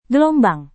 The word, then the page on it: gelombang: Indonesian